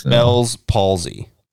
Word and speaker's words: Bell's palsy: Am I saying it right? – Bell's palsy.